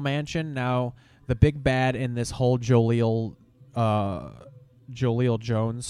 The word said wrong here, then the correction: Joelle Jones: story, I don't know if I'm saying her name right, Joelle Jones. – I think it's Joelle Jones.